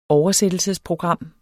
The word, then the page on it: oversættelsesprogram: dansk